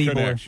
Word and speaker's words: Ybor: It is Ebor, by the way. – It's Ebor. Ebor. It's spelled Y-B-O-R, but it's called pronounced Ebor City.